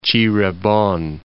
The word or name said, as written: Cirebon